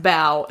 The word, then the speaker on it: bau: Bow. B-A-U. – Yep. B-A-U. Okay. – But it's pronounced bow as yep. in the dumpling.